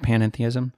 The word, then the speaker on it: panentheism: Okay, well, so going back to this idea of panentheism, am I pronouncing it right, panentheism? – Yeah.